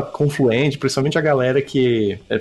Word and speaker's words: Confluent: Eu, eu pronuncio Confluent porque é como eu ouço da boca dos, do, dos donos da empresa, né?